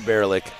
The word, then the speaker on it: Bierlich: Sorry, I'm going to mispronounce it a lot because it looks like Bierlich to me. – Yeah, no, say Bierlich. Just remember, does a Bierlich in the woods.